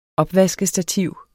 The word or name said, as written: opvaskestativ